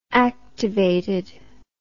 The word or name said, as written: activated